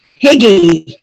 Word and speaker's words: Hygge: There's another oil called, well, depending on who you're listening to, but I've heard it pronounced Higgy.